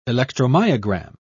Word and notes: electromyogram: British English

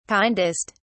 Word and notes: kindest: British English

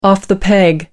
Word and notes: off the peg: American English

